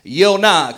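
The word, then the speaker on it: Yonah (יונה): Jonah in the original Hebrew is pronounced Yonah.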